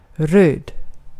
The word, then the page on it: röd: /røːd/; /røː/